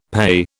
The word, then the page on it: PAYE: British English